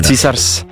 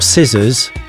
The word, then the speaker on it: Caesars: Love the pronunciation there. – Caesars. – Not scissors. – Caesars. – Caesars. – Caesars. – Like Caesar, like Julius Caesar.